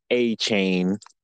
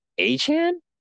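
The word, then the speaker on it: Achane: He had that big game and said he didn't want to be called A-Chain. – Right. – It's A-Chan? – A-Chan.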